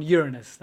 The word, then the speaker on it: Uranus: And they actually had a homemade telescope and they found Uranus. That's how you pronounce it.